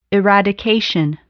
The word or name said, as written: eradication